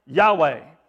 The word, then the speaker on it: Yahweh: So in the Hebrew, it's this way, if we look at it in the English, it'd be a Y and an H and a W and an H. Pronounce Yahweh.